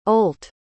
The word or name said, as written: Olt